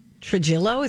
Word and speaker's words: Trujillo: Metallica bassist Robert Trujillo, is that how you say it? – Yes.